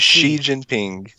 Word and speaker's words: Xi Jinping: Xi Jinping is Xi Jinping. – Xi Jinping, yeah, is getting psycho over religion.